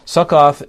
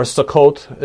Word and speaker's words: Sukkot: And then he said to the men of Succoth, Succoth is or Sukkot is how you'd pronounce it, I believe, in Hebrew.